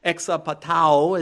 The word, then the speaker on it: exapatao: He says, I fear lest somehow, as the serpent deceived, there's that Greek word again, exapatao is how it's pronounced in the Greek.